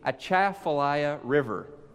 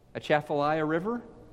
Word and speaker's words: Atchafalaya: It's a 137-mile-long river that apparently I mispronounced at first service. Several Louisianans came up to me and shared this with me. I'll probably mispronounce it again at second service, but I'm going to try to do better. It is the Atchafalaya River. Anybody from Louisiana here? Atchafalaya River? Sound good?